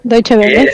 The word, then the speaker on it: Deutsche Welle: Deutsche Welle, sí.